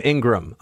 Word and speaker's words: Ingraham: Aries writes that Laura Ingraham pronounces her name Ingraham.